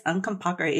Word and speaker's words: Uncompahgre: Yes, Uncompahgre, yes.